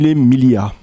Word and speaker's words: Mille Miglia: Et pour se refaire, eh bien, il décide de tout miser sur une course, la Mille Miglia. Je ne suis pas sûre de bien le prononcer en italien. – C'est Mille Miglia. – Merci. La Mille Miglia, 1000 miles à travers l'Italie.